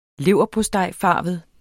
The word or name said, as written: leverpostejfarvet